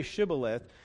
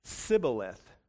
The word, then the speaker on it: Shibboleth: They could not say Shibboleth. When they tried, it came out as Sibboleth, which apparently means a burden.